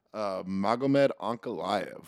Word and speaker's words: Magomed Ankalaev: He's going to be on this card against Magomed Ankalaev. – Fucking beautiful pronunciation. – I'll say, wow, you actually really good. – Really nice. – That was really good.